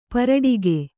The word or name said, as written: ಪರಡಿಗೆ